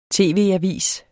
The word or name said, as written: tv-avis